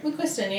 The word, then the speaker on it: McQuiston: So we're talking about Red, White, and Royal Blue by Casey McQuiston. That seems right, right? – McQuiston, yeah.